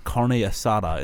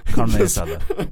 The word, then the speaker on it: carne asada: That is the correct pronunciation. It's not carne asada. It's carne asada.